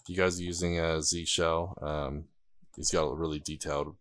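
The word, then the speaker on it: zsh: Oh my, is it Z-shell? How do you pronounce it? I don't know. – Z-shell.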